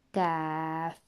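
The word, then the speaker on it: Kaf (ك): It is very important to remember from the previous lessons that one letter is pronounced as Qaf because it is a full mouth heavy letter, and this one that we are studying at the moment is pronounced as Kaf because it is empty mouth letter.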